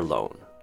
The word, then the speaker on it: loan: It's a silent B, so it's simply D-E-T, debt. Next word, loan. Loan. With this word, try saying the word own, and then add an L in front of it to become loan. That's how you pronounce loan.